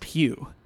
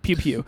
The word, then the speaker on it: Pugh: I want you to talk about, I think her last name is pronounced Pugh. – Yeah, Florence Pugh. Like Pew Pew.